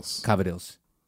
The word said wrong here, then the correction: cavatelli: Called Cavatelli. It's the way it's spelled.